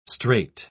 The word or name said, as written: strait